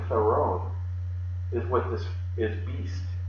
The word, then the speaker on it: Theron: So the Greek word is Theron, just like Charlize Theron, the actress.